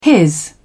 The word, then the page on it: his: British English